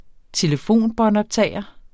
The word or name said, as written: telefonbåndoptager